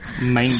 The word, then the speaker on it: manga: Manga. – So the mess you guys can't even pronounce it correctly, by the way. – Manga? – It's manga.